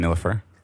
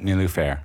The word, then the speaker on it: Nilüfer: How's it going, Nilüfer? – It's good. – Am I saying that correctly? – Yeah, almost. Nilüfer. – Nilüfer. Mm-hmm. Okay.